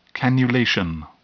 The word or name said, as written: cannulation